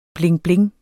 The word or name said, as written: blingbling